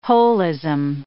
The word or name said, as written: holism